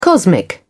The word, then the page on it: cosmic: British English